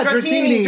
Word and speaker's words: Dratini: Dratini. – Yeah, Dratini.